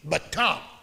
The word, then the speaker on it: batach: In English, we would spell it B-A-T-A-C-H, batak.